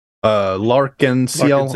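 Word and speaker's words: L'Arc-en-Ciel: Hyde from Larkin Seal.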